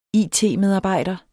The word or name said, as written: it-medarbejder